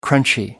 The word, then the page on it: crunchy: American English